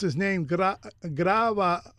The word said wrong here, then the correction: Grijalva: Grijalva. – How do you pronounce it? – Grijalva.